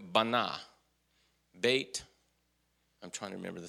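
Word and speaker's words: bait (בית): Now, the bait, that is a bait, pronounced bait.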